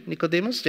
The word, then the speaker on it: Nicodemus: Nicodemus, the right pronunciation? Nicodemus, yeah.